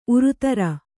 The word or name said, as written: ಊರುದೊರೆ